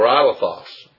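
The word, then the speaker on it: alethos (ἀληθῶς): Indeed is the Greek form alethos, or alethos, probably, is the correct pronunciation, or closer to the correct Alephos would mean truly.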